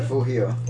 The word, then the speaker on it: Refugio: Is that how you pronounce it? – Refugio. – Okay. – It means refuge.